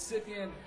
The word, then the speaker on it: Scythian: It's Scythian.